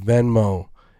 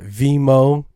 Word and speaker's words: Venmo: And even though he'd be butchering the fuck out of the word Venmo, Rand, it's called Venmo, not Venmo. It's called Venmo, V-E-N-M-O.